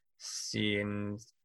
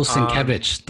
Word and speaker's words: Sienkiewicz: And then the third part is by Sam Humphries, pencils by Dennis Cowan, inked by Bill Sienkiewicz, colors by Chris Sotomayor. – Bill Sienkiewicz, the legendary Bill Sienkiewicz. – Bill Sienkiewicz. Oh, wow. Yeah. Okay, that's not how I would pronounce that, but I apologize.